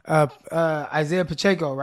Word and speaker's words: Isaiah Pacheco: Isaiah Pacheco, right? Did I say that right? – Yep, you got it.